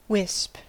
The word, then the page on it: wisp: American English